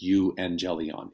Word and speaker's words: evangelion: We just, the U becomes a V phonetically because it's followed by a vowel. So it's you, angelion.